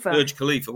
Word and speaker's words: Burj Khalifa: Burj Khalifa, well done.